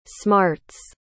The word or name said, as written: smarts